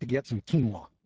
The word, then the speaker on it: quinoa: No, it's called quinoa. Quinoa. That's the way you pronounce it.